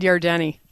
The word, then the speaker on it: Yardeni: Is that how you say his name, Yardeni? – Ed Yardeni.